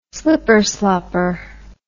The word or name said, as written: slipperslopper